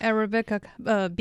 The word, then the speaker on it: Arabica: Prices hit a 40-year high back in 2024 for, how do you pronounce that, Arabica? – Yeah, Arabica beans.